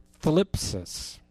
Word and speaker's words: thlipsis: It's the word thlipsis. T-H-L-I-P-S-I-S.